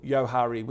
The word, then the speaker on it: Johari: I remember early doors, 10, 15 years ago, we did some work on a thing called the Johari or Yohari window. Depends on what side of the Moonee Ponds Creek you grew up on, Steve-O. But I think it's spelled J-O-H-A-R-I, Johari window.